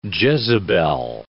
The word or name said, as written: Jezebel